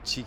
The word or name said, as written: chick